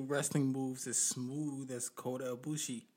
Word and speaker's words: Kota: K-O-D-O. – Okay, this is Kota. K-O-T-A.